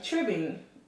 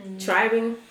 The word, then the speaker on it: tribbing: Okay, so here's a topic, tribbing. – Tribing? – It's tribbing. – Why is... – Because a lot of people say tribing.